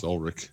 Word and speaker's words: Ulrich: No, he's not the drummer for Metallica. It's Ulrich.